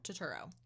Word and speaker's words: Turturro: Turturro? – Turturro.